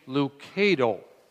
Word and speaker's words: Lucado: Is it Lucado or Lucado? For the record, he says, we say Lucado.